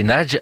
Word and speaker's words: Nagy: Bien justement, nous allons écouter Imre Nagy. Alors j'essaye de le prononcer bien, mais mon hongrois n'est pas parfait. C'est Nagy, hein, N-A-G-Y, lu aujourd'hui dans le cours de l'histoire sur France Culture par Vanda Benes.